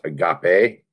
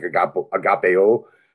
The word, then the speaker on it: agape: And that's the Greek word, I think it's pronounced agape. Mm-hmm. Looking at it, it looks like agapeo, but I think it's pronounced agape from some people I've talked with.